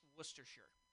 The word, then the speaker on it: Worcestershire: Who comes from West Norhamshire. – I don't think that's how it's pronounced. – It's pronounced Worcestershire.